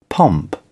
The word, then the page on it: pomp: British English